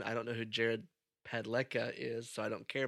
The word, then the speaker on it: Padalecki: So, Supernatural star Jared Padalecki, or however you say his name. – Padalecki.